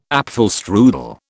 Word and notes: apfelstrudel: British English

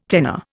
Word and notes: tenor: Indian

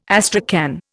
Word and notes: astrakhan: Indian

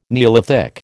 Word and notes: neolithic: American English

